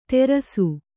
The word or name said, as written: ತೆರಸು